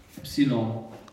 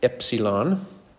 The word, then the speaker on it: Epsilon: Epsilon. – Epsilon. Your E sound. But it's a short E. So notice even here it's like Epsilon. – Eh, as in get.